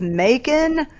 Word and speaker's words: Macon: What rhymes with Macon? A very delicious Shaken. – Food? Sure, but another... – Bacon. – There you go.